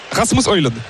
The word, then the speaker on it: Rasmus Højlund: Rasmus Højlund.